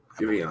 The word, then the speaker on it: Giveon: Giveon.